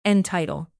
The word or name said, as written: entitle